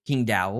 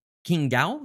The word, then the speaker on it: Qingdao: And so they have this, they're building this huge studio in Qingdao, or I know I just butchered that, by the way. It's I think it's King Gao.